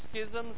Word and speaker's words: schisms: How do you say that? Schisms with a hard C.